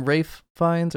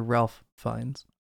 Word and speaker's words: Ralph Fiennes: Now, do you guys call him Rafe Fiennes or Ralph Fiennes? – I always say Rafe Fiennes. – Why do they say Rafe? Is it a French pronunciation or something? – I don't know.